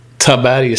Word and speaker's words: Taberg: Hur uttalar man det här ortsnamnet egentligen? Ska det vara kort eller långt A? Säger man Taberg eller Taberg eller Taberg? Vet du? – Taberg ska det vara.